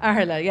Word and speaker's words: Aherla: Aherla, yeah.